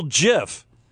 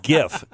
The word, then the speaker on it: GIF: what a cool GIF. No. It's GIF. G-I-F. GIF with a hard G.